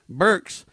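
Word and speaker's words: Birx: So Richard Mack saying there were several people surrounding the Donald, Fauci and Deborah, how do you say her last name? – Burks are the two main doctors in this thing.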